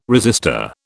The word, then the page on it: resistor: British English